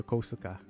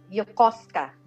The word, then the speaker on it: Yokosuka: Okay, so yun yung pag-pronounce nyo, Yokosuka. – No, Yokosuka. Like, diretso lang.